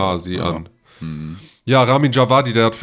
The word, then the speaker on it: Ramin Djawadi: Ramin Djawadi. – Ramin Djawadi, genau.